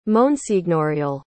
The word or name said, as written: monsignorial